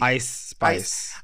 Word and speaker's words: Ice Spice: And you know, the younger generations are listening to these Lottos and these Megans and Icy Spice. Is it Icy or Ice Spice? How do you say her name? Ice Spice or Icy? – Ice Spice.